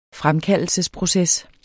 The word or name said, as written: fremkaldelsesproces